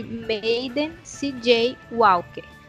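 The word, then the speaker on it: Madam C. J. Walker: Maiden C.J. Walker. Segundo os estudiosos da internet, é assim que se pronuncia.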